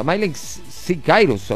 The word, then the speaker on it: Miley Cyrus: Sí, así se pronuncia, Miley Cyrus o Cyrus.